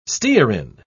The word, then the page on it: stearin: British English